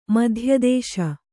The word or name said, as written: ಮಧ್ಯದೇಶ